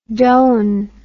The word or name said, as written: doane